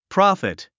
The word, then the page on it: profit: prɑ’fit; プロォフィット